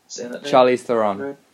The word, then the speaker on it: Charlize Theron: So there's Margot Robbie, Charlie the Theron. – Yeah. Charlize Theron.